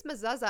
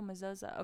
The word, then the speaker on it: mezuzah: I think it's mezuzah. I remember Lil Dicky saying something in an interview about his mezuzah. – Oh, mezuzah. Okay, I agree then.